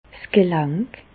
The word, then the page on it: 's Gelänk: Bas Rhin; Reichshoffen